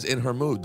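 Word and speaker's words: In Ha Mood: This one's spelled In Hamood. But she just says in her mood the whole song. There's not even, it's not even close to AJ. – In her mood.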